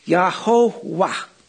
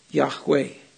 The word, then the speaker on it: Yahweh: The result was that all future readers would say Yahowah rather than as is it probably should have been pronounced, Yahweh.